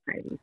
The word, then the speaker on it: Eide: So it is pronounced Eide, correct? – Correct. – Eide. I need to remember that. So I think I used to say Ida instead of Eide. – Actually, you'd be correct because that would be like the Norwegian pronunciation, which is what this is, but the American version is Eide. So yeah, you're not wrong.